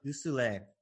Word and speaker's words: Du Soleil: I am talking to, how do you say this, Oz Du Soleil? – Du Soleil, yep. – Du Soleil, who is on fire with Excel.